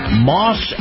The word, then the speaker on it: Maus: Now, this portion of the program is brought to you by Moss Acura. Moss is spelled M-A-U-S,